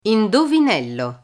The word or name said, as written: indovinello